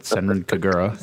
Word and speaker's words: Senran Kagura: Segun Kagura. – Oh, my God. – Best game. – He said it wrong with Senran Kagura.